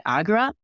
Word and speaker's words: Agra: Okay, Escaping Agra.